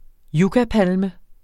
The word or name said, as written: yucca